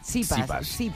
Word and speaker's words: Tsitsipas: Tsitsipas.